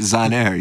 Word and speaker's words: Zahner: That's from Guy, do you pronounce it Zahner? Z-A-H-N-E-R? – I think that's Zahner, yeah.